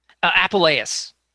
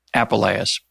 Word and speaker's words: Apuleius: Apuleius. – Apuleius.